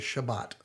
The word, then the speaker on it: Shabbat: Now, the Hebrew pronunciation for that is Shabbat. So you will hear the word Shabbat all the way through episode 2.